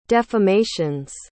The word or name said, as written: defamations